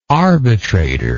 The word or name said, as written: arbitratorship